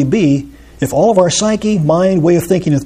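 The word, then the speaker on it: psyche (ψυχή): The word soul, there's a Greek word psuche, or psyche, as we pronounce it.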